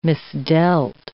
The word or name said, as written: misdealt